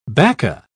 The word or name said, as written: bacca